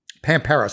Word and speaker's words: Pampero: How do you pronounce, is it Pampero, or something else? – I've always gone with Pampero. – Pampero.